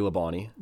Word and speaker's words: Labonte: It's Bobby Labonte. – Yep. Is that how you pronounce that? – Yeah.